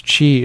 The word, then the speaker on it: Qi: Because you do a free consultation, a phone consultation, or as an initial consultation, and your website, even though it's pronounced Qi, is spelled Q-I.